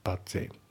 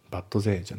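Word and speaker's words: but they: で、あと発音ですけど、バットの T が抜けますね。バッゼイ。バットゼイじゃなくて、バッゼイ。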